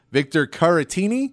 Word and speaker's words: Caratini: We did not hear, though, Victor Caratini. I believe is how you pronounce that name. – Yes, I believe so.